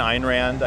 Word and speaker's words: Ayn Rand: Ayn Rand, Ayn Rand, I guess is how you really say her name.